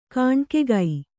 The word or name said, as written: ಗಣಕಿಗ